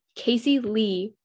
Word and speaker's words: Kcey Lee: My handle is at Casey Lee, but my name is spelled without the A, so it's just K-C-E-Y-L-E-E,